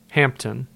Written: Hampton

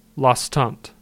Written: Lostant

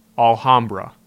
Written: Alhambra

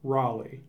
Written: Raleigh

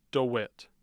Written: dewitt

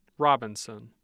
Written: Robinson

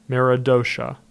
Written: Meredosia

